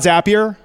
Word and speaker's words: Zapier: So you heard about Zapier, right? Zapier rhymes with happier.